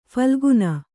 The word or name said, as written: ಫಾಲ್ಗುನ